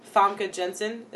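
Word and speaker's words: Famke Janssen: Famke Jensen.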